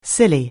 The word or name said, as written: silly